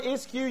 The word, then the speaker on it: ischuo: Now that word I can do, that word I can do means, it's a Greek word meaning isku, okay? And if you use that word isku, you can spell it I-S-C-H-U-O.